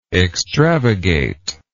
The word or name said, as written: extravagation